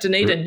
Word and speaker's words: Dunedin: And in fact, you nailed the pronunciation. Some people go for a bit of a Dunedin, but Dunedin, yeah, perfect.